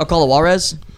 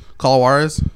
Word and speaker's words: Call of Juarez: Call of Huervos? Huervos? – Oh, Call of Juarez? – Call of Juarez? – Yeah, the race is...